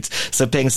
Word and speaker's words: Sepang: Did I say it correctly? Sepang circuit. – Sepang. – Yeah. That's more like it. Sepang circuit.